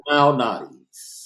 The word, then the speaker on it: Malnati's: Lou Malnati's.